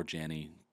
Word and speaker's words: Janney: And by the way, you could pronounce my last name Janney or Janney. Doesn't really matter.